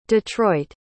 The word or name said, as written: Detroit